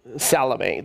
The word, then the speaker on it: Salome: Salome, that's her name, Salome. It's spelled like salmon or salome, but it's Salome.